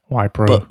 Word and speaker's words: Wipro: I've been trying to wait until I felt like we had, you know, more details, because it's quite tricky, and it's a company called Wipro. We think that's how you pronounce it. – Yeah, Wipro.